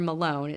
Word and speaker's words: Malone: On July 24th, I don't know if it's pronounced Maloney or Malone. It's M-A-L-O-N-E. Malone. – It sounds like Malone to me.